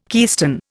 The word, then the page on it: keystone: Indian